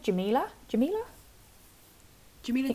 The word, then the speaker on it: Jamila Jamil: Is she called Jamila? – Jamila Jamil? – That's correct.